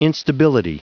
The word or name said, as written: instability